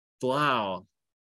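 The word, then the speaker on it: Blough: Yeah, it was David Blau or Bluff. – That's right, Blau. – He was. Was he a Blau or a Bluff? I remember his pronunciation. Blau. David Blau.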